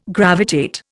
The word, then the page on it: gravitate: Indian